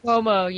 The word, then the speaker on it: Cuomo: So that was Mayor Cuomo. Is that how you say his name? – Cuomo, yeah.